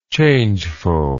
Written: changefulness

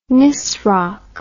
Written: nisroch

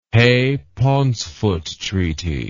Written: Hay Pauncefote Treaty

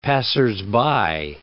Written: passersby

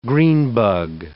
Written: greenbug

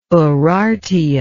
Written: urartian

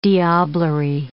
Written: diablerie

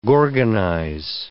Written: gorgonize

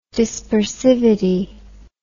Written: dispersivity

